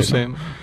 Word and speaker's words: Luce: No sé cómo se pronuncia, yo creo que es así, sí, Luce, ¿no? – Luce.